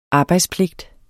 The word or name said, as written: arbejdspligt